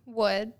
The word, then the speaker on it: Wood: Wood.